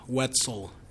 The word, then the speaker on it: Wetzel: Sheriff Mickle, M-I-C-H-E-L, Wetzel, W-E-T-Z-E-L.